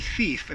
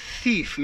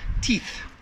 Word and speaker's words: thief: I encountered my first and only thief in this country. And that's our word. One thief. Make sure that TH sound is pronounced. I hear all the time, teeth, teeth.